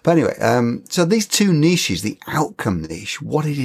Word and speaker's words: niche: Those you might call your niches or niches, as you say in the States. – Yeah. – Incorrectly, by the way. It's a French word.